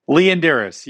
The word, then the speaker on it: Leondires: Leon Dyrus.